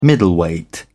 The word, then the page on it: middleweight: British English